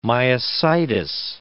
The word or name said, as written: myositis